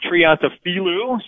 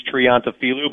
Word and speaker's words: Triantafilou: It's Triantafilou. So just one syllable off there on the on the Fanatic.